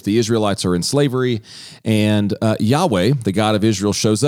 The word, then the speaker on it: Yahweh: And in Hebrew, you would pronounce it as Yahweh.